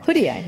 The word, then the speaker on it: Hoodia: Hoodia, yeah.